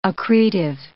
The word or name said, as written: accretive